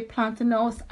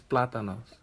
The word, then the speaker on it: plátanos: You say plantanos, I say plantain. – It's plátanos.